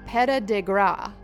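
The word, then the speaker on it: Petit-de-Grat: Note, Petit de Gras is pronounced like Peta de Gras, and then there's a link to this.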